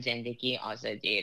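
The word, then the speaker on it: Zendegi Azadi: Zan Zindagi Azadi. That that's how you say it in Persian. Okay.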